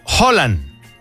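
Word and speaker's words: Haaland: La pronunciación correcta del apellido del futbolista se pronuncia de manera aproximada como Holland. Más detalle con mi comentario de abajo porque ya no alcanzo a escribirlo aquí. Dice, la H inicial se aspira suavemente, como una J muy leve.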